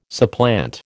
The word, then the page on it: supplant: American English